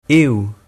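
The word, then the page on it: eou: Provençal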